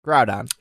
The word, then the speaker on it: Groudon: Yeah, but I do have Kroger, and I have Groudon. And Groudon. Come on, say it right. Groudon.